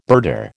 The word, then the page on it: birder: American English